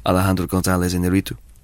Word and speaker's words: Alejandro González Iñárritu: Alejandro González Iñárritu.